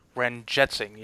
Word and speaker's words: Ranjit Singh: You know, if their keeper didn't come up with a tremendous save there, I don't know how to pronounce his last name, Greg Ranjit Singh. – I'll go with that. Ranjit Singh, yep.